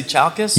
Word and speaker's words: Chalkis: It's Chalkis. – Did I say Chalkis? – Yeah. You did. – Son of a... – You're so proud of yourself. – I knew it. And I said I had like three versions of how to pronounce it I practiced in my car even, and none of them were right. Yeah. So he corrected me. It's chalk, like a chalkboard, S, Chalkis. Yeah.